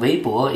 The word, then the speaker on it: Weibo: Also eigentlich, ich bin ein super royaler Nutzer von Weibo. Ich nutze Weibo täglich vielleicht zwei Stunden. Weibo funktioniert mir als eine erste Quelle für was in China passiert ist, weil ich in Deutschland lebe.